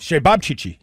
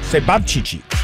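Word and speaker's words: Ćevapčići: Shababchichi. Yes, okay. It's C-E-V-A-P-C-I-C-I. And it's sort of a Serbian or a Croatian sausage. It was delicious, by the way. Shababchichi.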